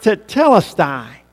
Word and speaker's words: tetelestai: And it is the word that is said, or pronounced, tetelestai.